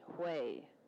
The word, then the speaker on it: Hue: And you know how Wei is spelled? H-U-E, Wei. Or, like Benoit is H-O-A, Benoit.